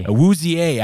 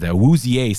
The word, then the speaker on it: Awuzie: Cincinnati lost its best cornerback when Chidobe Awuzie, is that how you say his name? I don't even know how to say his name. Well, he departed the first half. – Awuzie, actually, that sounds right. Now that's triggering something in my head. Awuzie sounds right.